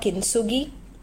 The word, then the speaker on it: Kintsugi: Have you all heard about Kintsugi? It is spelled as K-I-N-T-S-U-G-I, Kintsugi.